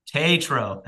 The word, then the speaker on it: Tatro: It stars Ben Platt, Molly Gordon as well, Noah Galvin, and Jimmy Tatro. Tatro, thank you. I always get some mispronunciation on the pod.